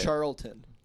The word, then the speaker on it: Charlton: Actually, a fun fact: Charleston Heston played Charleston Heston. – What's his name? Charles? How do you say it? – Charlton. – Charlton Heston.